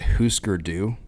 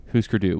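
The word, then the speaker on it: Hüsker Dü: I always thought it was Husker Du. I don't know how to say it. – In the documentary for this album, they pronounce it Husker Du. – Husker Du, which this shows how not cool we are.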